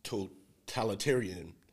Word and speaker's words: totalitarianism: Back to Live Not By Lies, the totalitarianism that they're promoting, the totalitarianism that they're, I know I don't say it right. Let me see. – Totalitarianism. – Yeah, that part. Thank you.